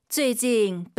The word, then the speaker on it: 最近: 最近北京热死了. – 쯔이가 아니라 UI 사이에 에 넣어서 쯔에, 쯔에 이 발음 기억하시면 좋을 것 같습니다.